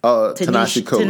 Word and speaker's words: Ta-Nehisi Coates: I mean, because they mention, I'm going to mispronounce his name, and I'm so sorry. Tahishi? – Tinashe Coates. – Tinashe Coates.